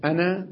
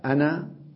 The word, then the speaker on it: أنا: قال وكتبوا الألف أنا مع أنها لا تلفظ في درج الكلام، هذا مما يكتب ولا يلفظ الألف، لأنها إذا وقف عليها وقف عليها بالألف. أنا لما أقف على أنا أقف عليها بالألف، ولا أقول أن.